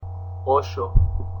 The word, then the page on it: Osho: pronunciacion